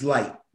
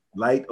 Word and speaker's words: Licht: How do you pronounce his last name? L-I-C-H-T? – I believe it's Light. – Light, okay.